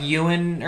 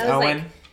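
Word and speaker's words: Eoin: That looks like Ewan or something like that, but it's Owen.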